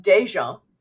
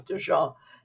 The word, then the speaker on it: DeJean: My name is pronounced DeJean in Louisiana. Yeah, but I have given up trying to explain this variant long ago, so I just go with DeJean.